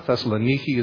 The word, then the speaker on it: Thessaloniki: Thessaloniki is how they pronounce it in Greek.